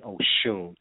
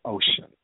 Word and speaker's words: Oshun: Oshun. But then you might turn on your local news and hear Oshun pronounced like ocean, you know, which is a way of denying the power thereof, if you will.